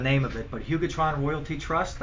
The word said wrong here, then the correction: Hugoton Royalty Trust: It's Hugoton Royalty Trust. – Hugoton Royalty Trust.